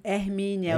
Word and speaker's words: Hermínia: Hermínia mesmo? Hermínia, é o nome da minha avó, é nome forte, avó. É? que eu amo. É que eu pronuncio assim mesmo.